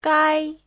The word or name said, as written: gai1